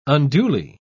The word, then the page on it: unduly: British English